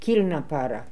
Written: kilnapara

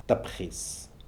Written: Tpkhis

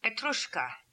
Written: Petrushka

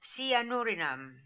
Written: Sianoorinam

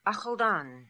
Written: Akhaldan

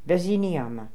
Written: Veziniama

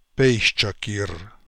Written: paischakir

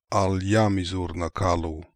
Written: Aliamizoornakalu

